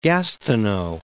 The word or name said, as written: Gastineau Channel